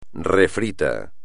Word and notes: refrito: espagnol

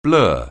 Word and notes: blur: anglais

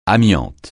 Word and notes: amiante: francais

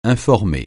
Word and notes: informer: francais

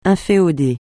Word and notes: inféoder: francais

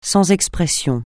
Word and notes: sans expression: francais

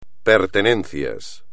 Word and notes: pertenencias: espagnol

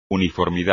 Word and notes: uniformidad: espagnol

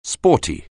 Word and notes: sporty: anglais

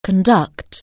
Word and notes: conduct: anglais

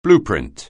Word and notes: blueprint: anglais

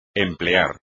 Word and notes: emplear: espagnol

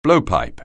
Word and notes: blowpipe: anglais

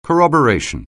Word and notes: corroboration: anglais

